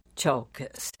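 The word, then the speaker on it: Tsiolkas: I want to spell for you his last name. It's T like Tom, S like Sam, I-O-L-K-A-S. It's pronounced Tsolkas.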